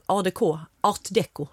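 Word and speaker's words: Art déco: ADK. Art Deco. Förlåt att jag snobbade till mig med det franska uttalet.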